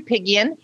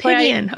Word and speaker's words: Apigian: It's Dr. Apigian, but that's Apigian. That's why I go by Dr. Aimie. Because there's so many different ways that you can say that.